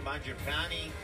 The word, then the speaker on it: Mangiapane: Smart player, Andrew Mangiapane.